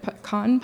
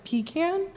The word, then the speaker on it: pecan: I pronounce it pecan pie. And Mike says pecan. – It's a pecan pie.